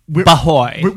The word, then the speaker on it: B'hoy: Bahoy. – Believe you pronounce it like boy because it's from the Irish pronunciation because a lot of these men were Irish.